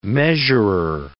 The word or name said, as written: measurer